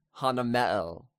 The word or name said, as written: Hanameel